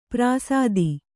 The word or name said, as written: ಪರಸತಿ